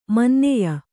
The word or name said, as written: ಮಾನನೀಯ